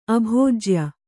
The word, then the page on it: ಅಬೀಜೀಯ: abījīya